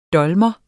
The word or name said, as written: dolmer